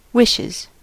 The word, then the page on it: wishes: [ˈwɪʃ.ɪz]; American English